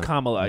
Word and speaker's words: Kamala: It's pronounced Kamala, yes.